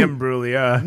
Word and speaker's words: Imbruglia: No, it's Natalie Imbruglia. – No. – It's Natalie Imbruglia. – It's Natalie Imbruglia. You missed a syllable.